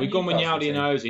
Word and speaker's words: Wijnaldum: Wijnaldum.